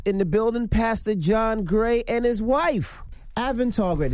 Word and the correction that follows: Aventer: Did I say that right? – Aventer. – Aventer.